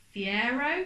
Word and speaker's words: Fiero: Fast and Furious 9, the spacebound Pontiac Fiero.